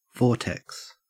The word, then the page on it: vortex: Australian English